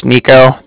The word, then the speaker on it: Niko: So, like I said, my name's Nico. That's spelled N-I-K-O. – Okay. – And then the Bank of Cardiff is spelled C-A-R-D-I-F-F, as in Frank.